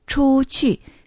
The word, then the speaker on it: 出去: それから、出かける、出去、出る。それから、来たる猿のその猿っていう字を書きます。出去。出の発音は CHU。建設音、有機音、一世。